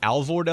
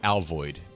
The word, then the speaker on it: Alvord: I say Alvord Elementary, but I heard the musers saying this morning that some people say Alvord, but it's A-L-V-O-R-D. I've always heard that city pronounced that way.